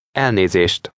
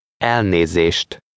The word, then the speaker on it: Elnézést: Elnézést. – Note that in Hungarian, the stress is always on the first syllable of every word.